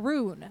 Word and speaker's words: Rhun: King Rune, which is spelled R-H-U-N.